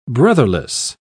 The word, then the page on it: brotherless: British English